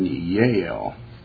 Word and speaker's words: Jael: And even Yael, it's spelled J-A-E-L, in the fourth and fifth chapter of Judges, where she was the one, Yael was the one that defeated Sisera.